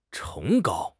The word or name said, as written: 崇高